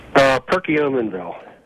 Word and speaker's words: Perkiomenville: Am I pronouncing that right? – Perkiomenville. – Perkiomenville, okay.